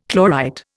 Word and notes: chlorite: Indian

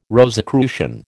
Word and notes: Rosicrucian: American English

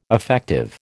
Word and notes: affective: American English

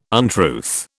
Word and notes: untruth: British English

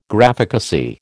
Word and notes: Graphicacy: American English

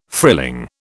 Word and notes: frilling: British English

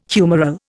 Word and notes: humoral: Indian

